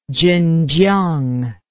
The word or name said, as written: zhenjiang